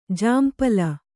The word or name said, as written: ಝಂಪಳ